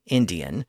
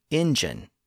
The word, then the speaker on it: Indian: It was originally Indian, but then the same sound change that we've seen converted the consonant in the middle to engine. But eventually, the original pronunciation Indian came back into fashion and is now the standard pronunciation.